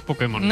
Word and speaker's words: Pokémon: Para permitir que Putemón. – El poder que hay en Pokémon. – Putemón. – Hazte con solo. – Pues hazte con todos, Pudemón.